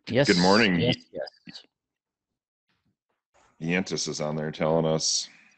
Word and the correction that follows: Giannis: It's Giannis. Can't say his name wrong anymore. – Giannis.